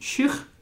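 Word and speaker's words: sheugh: So it turns out the Scots pronounce it shook. – Okay. – But it's definitely shook.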